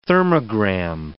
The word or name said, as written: thermogram